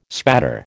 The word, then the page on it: spatter: American English